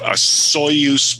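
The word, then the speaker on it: Osoyoos: So I am located at a place called Soyuz, BC. So that's spelled Soyoos. And it's a Soyuz, BC.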